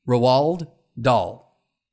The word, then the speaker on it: Roald Dahl: R-O-A-L-D, Roald Dahl.